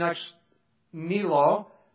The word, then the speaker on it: ex nihilo: Now, again, as I've mentioned, evolutionists believe, in a sense, creation ex nihilo. There's actually three syllables there.